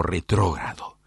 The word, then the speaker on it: retrógrado: Hablo de lo retrógrado, así está bien pronunciado.